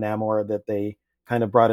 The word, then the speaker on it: Namor: Well, this version is Namor, and they give a reason why he's pronounced that way. Because it's Mayan culture.